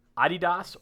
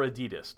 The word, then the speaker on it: Adidas: Number 8, Adidas or Adidas, depending on where you want to pronounce that.